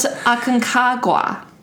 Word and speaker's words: Aconcagua: The highest mountain outside of Asia is in the Andes, Mount Aconcagua. I had a shot to do this once, and now I still can't pronounce it. – It's okay. – Mount Aconcagua. Yes.